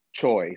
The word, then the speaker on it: Choi: And like when you actually look at Choi, C-H-O-I, and you actually think about it, it's like, how the hell do you screw that up?